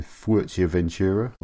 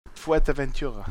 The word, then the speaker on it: Fuerteventura: Is it just Future Ventura? Is the way they pronounce the spell it? – Fuerteventura. – Fuerteventura. Because of course it's Spanish.